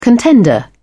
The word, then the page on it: contender: British English